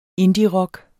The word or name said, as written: indierock